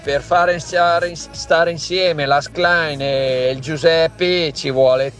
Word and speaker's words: Schlein: Sì, dai, intanto non è difficilissimo, impariamo il nome, Schlein, no, Schlein, cioè non si può sentire, caro ascoltatore, Schlein, non è difficilissimo, anche se sì, non sappiamo il tedesco.